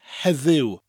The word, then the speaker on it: heddiw: And that's because in Swansea and the surrounding area, we tend to pronounce the letter E with more of an A sound. Not heddi, but heddi. But the more correct way is heddiw, or shortened heddi.